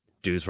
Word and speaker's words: Dews: Dews rhymes with booze.